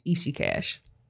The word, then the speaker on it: Ishiiqash: My name is Ishi Cash on all platforms. That's YouTube, that's Instagram, that's Twitter, that's Facebook, and that's spelled I-S-H-I-I-Q-A-S-H, Ishi Cash.